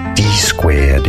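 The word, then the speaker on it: DSQUARED2: D squared is how it's said, because it was founded by two gentlemen.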